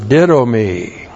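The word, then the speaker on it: didomi (δίδωμι): By the agency of the Holy Spirit, and then the verb didomi. Looks like that in the Greek, D-I-D, long O-M-I, didomi.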